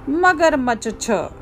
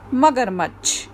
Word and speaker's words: मगरमच्छ: अगर हम पूरा च लिखें तो ये क्या बन जाएगा मगरमच्छ छ जबकि इसका सही उच्चारण है मगरमच्छ